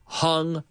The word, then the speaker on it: hung: Okay, the pronunciation hang, hung, hung, hung. It rhymes with tongue, como la lengua, tongue.